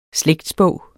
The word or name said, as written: slægtsbog